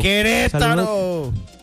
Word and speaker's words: Querétaro: Dice, me perdona la pronunciación, que te taro, que te taro. – Querétaro.